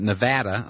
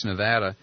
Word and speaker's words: Nevada: Jerry, who has called in from Sparks, Nevada. Nevada, I'm sorry. I pronounce it like Bush, and I shouldn't. Sparks, Nevada.